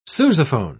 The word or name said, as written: sousaphone